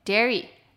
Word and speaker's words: dairy: 那如记法呢，我们就可以看到 diary，因为它的发音是 di 在前面，所以就是 di diary diary，乳制品的 ai 则是 a dairy dairy。